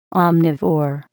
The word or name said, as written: omnivore